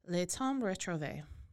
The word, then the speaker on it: Le Temps Retrouvé: I think that you pronounce this as Le Temps Retrouvé.